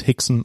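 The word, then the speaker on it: Rickson: Heus Gracie Heus, war das, genau. genau. Alle R's immer wie H aussprechen, genau. – genau. Heus und Hickson und ähm, genau, deswegen ist es eigentlich Brazilian Jiu-Jitsu, die Amis zum Beispiel sagen ganz auch, auch einfach nur Jiu-Jitsu.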